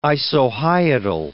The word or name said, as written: isohyetal